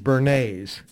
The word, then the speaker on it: Bernays: And one of the leaders of this was a guy named Edward Bernays. Now, the way to remember his name is to think of Bernays sauce, mm-hmm. – but it's spelled B-E-R-N-A-S-E.